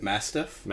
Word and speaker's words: mastiff: And it's later reported that a large mastiff, is that how you say it? – Mastiff? – Mastiff, which is a huge dog, like honestly could stand for, or fight for itself.